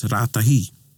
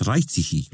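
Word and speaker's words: Raetihi: So, ane tetahi, I used to hear about this place, Rātahi. I used to hear, Rārahi. And then I thought, oh, the place must be called Rātahi. – Yeah. – And then I pass through it and it's Raitihi.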